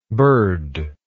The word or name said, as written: burd